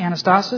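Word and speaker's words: Anastasis (ἀνάστασις): Anastasis, is that how you say it? – Anastasis. – Anastasis.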